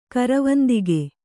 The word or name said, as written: ಕರವಂದಿಗೆ